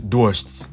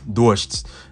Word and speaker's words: дождь: And just to notice a note on pronunciation, the word for rain is дождь, дождь. Now in Russian you have the D sound and then you have a soft the sound, which is called a мягкий знак.